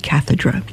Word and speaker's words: cathedra: The use of the term chair in the feast day comes from the Latin term cathedra, some pronounce it cathedra, meaning the seat of government.